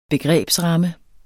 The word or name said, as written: begrebsramme